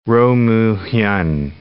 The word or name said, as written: Roh Moo Hyun